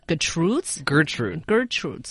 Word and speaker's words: Gertrude: 英国每日邮报登出最性感名字排行榜，亚历山德罗和斯嘉丽分别获得最性感男名和女名的冠军。And Bob's Gertrude, so it's the emphasis on the first syllable.